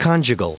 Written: conjugal

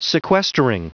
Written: sequestering

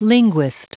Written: linguist

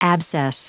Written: abscess

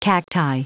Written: cacti

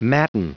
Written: matin

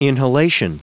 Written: inhalation

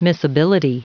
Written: miscibility

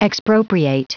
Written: expropriate

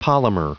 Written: polymer

